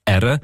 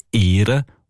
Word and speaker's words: ären: Im Schweizerdeutschen ändert die Aussprache von dem Verb «ären» je nach Region. Zum Teil sagt man «ären», in anderen Regionen «ehren» und zum Teil eben auch «ahren».